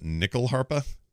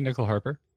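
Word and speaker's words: nyckelharpa: By the way, the stringed instrument used is called a Swedish, I'm not going to say this right, but nickel harpa? – I would say nickel harper. – N-Y-C-K-E-L harpa. So probably nickel, I guess.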